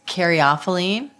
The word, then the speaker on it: caryophyllene: So it's caryophyllene. – Oh, yeah. – Caryophyllene. And I think there are two other ways, but it's...